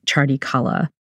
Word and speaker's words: Chardi Kala: So Chardi Kala is and nice pronunciation, by the way. Well done. It has a letter in there that most Americans have never heard before.